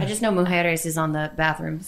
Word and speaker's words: Mujeres: I've always called it Isla Mujeres or something. – Mujeres? – Because soft J. – It's a soft J. – Haven't you listened to a Pitbull song ever? He says Mujeres.